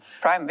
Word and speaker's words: Prime: Det er det som, jeg vet ikke om det uttales Prime eller Prime, ja, men Prime, vi går for det.